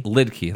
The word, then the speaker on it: Lidke: Lidke, like a lid and a key.